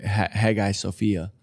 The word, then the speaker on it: Hagia Sophia: Hagia Sophia.